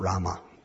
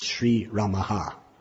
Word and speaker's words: Sri Ramaha: it's not Rama. It's Sri Ramaha.